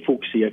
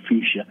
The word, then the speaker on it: fuchsia: I say fuchsia because that's how you're supposed to pronounce it, not fuchsia.